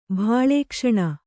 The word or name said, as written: ಭಾಳೇಕ್ಷಣ